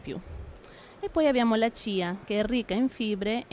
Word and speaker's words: chia: In realtà si pronuncia Chia, allora Chia.